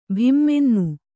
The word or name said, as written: ಭಿಮ್ಮೆನ್ನು